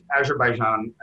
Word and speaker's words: Azerbaijan: At the same time, I don't know how to pronounce it, but Azerbaijan, or how do you say it? – Azerbaijan, I believe.